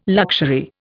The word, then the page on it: luxury: Indian